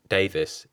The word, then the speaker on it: Davis: Tom is T-O-M, and my family name, Davis, is D-A-V-I-S.